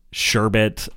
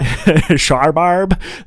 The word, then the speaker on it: sherbet: granita and sherbet and like anything else, and that's how that word is pronounced, by the way, anything else that like, oh, sherbet, yeah. – Sharbarb,